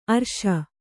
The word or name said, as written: ಆರ್ಷ